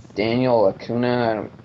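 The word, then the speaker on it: Daniel Acuña: Simple and, Daniel Acuna.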